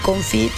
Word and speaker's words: confit: De el confit o el confitado. – El confit. Oye, pero si es francés, entonces ¿cómo se pronuncia? – Confit. – ¿Confit? ¿Así? – Sí. – ¿Como en inglés, no? – Sí.